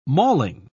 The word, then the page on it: malling: British English